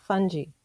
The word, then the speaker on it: fungi: You know, I say it with a soft G, so fungi. – Oh, fungi.